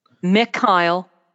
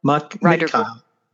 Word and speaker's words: Mikhail: You got my name. Uh, you mispronounced it. – Okay. What's the right? – Mikhail. Mikhail.